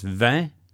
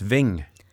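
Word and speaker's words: vingt: The number 20, pronounced anywhere in the French world, is vin, oui? – Vin. – But here it's ving.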